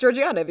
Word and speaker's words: Georgiana: You asked me earlier, how do you say Georgiana? And I was like, oh, it's Georgiana, because that's how the narrator of the audiobook said it.